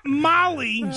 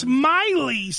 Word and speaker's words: Miley: It's not Molly Cyrus. It's Miley Cyrus.